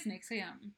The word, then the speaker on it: NXIVM: So what is Nixivm? It's spelled, by the way, N-X-I-V-M.